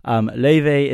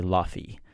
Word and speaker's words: Laufey: which is spelled L-O-E-W-E. But Loewe, which I hope I'm pronouncing correctly, I practice a lot. Loewe is actually an Icelandic name, and she is from, obviously, Iceland and Chinese. And it's spelled L-A-U-F-E-Y. Which is why people might say Lofi. But it's pronounced Loewe.